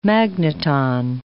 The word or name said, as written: magneton